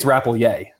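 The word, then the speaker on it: Rappleyea: Am I saying that right? – I think it's Rapoyea. – Rapoyea. Okay. All right, so Rapoyea.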